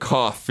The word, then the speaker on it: Koch: And then they quote, people at the studio, even us, honestly, sometimes in internal conversations or negotiations, we pronounce it Koch Media, even though it would be more like Koch in German, explained Weber.